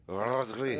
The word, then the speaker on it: Rodri: Is that how you pronounce it? – Rodri.